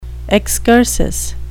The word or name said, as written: excursus